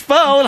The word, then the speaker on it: Pho: Yeah, it's not pronounced Pho. – Right. – Like, it would work if it's pronounced Pho, like 9021 Pho, but 9021 Pho.